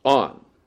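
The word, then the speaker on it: on (ἄν): And that is pronounced on, A-N.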